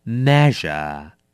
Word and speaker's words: measure: measure measure 动词计量名词尺寸那么这个字呢要小心一下它的发音哦是念成 measure 小心了没有止哦这两个音很多人会念不好的是 measure measure OK 是日不是止没有那么多止的音是 measure OK 我们多练两次咯 measure measure 第九个字 meter Meter, 名词，公尺，计量器。